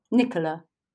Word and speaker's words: Nicola: Nicola.